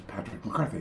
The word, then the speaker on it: Patrick McCarthy: He goes, I thought your name was Makashi And I said, Well, it is in, in katakana. In Japanese, katakana, that's my name. But I said, In English, the pronunciation is Patrick McCarthy.